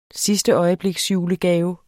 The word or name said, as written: sidsteøjebliksjulegave